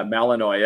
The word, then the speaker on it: Malinois: Yeah, Malinois.